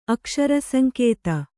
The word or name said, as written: ಅಕ್ಷರಸಂಕೇತ